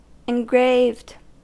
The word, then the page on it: engraved: American English